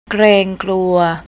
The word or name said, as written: kleangkluy